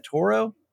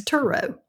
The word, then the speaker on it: Turo: How do you say that? Toro. – Mm-hmm. Like I don't know Toro, I can't say that right. Lawnmowers. – Toro. Yeah. – Yeah. – Okay. – It's Turo. Uh-huh.